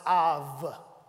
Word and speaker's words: av: So I looked up this word in my concordance, and it's a Hebrew word, and it's A-B, alpha, bravo, A-B, but it's pronounced, instead of ab, it's pronounced av. Everybody say av. So that's the way you pronounce it.